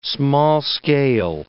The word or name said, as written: small-scale